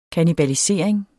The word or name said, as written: kannibalisering